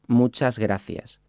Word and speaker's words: muchas gracias: So you could say, muchas gracias, which would be equivalent to thank you very much or thanks a lot. Let's break it down by syllable. Muchas gracias.